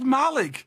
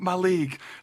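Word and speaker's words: Mallaig: It's called Malaig. It's called Malaig. Don't be saying Malaig.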